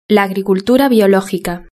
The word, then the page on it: la agricultura biologica: European Spanish